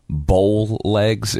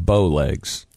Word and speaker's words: bow legs: But one that I do remember, I always used to say that I have bowl legs instead of bow legs.